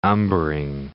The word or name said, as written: umbering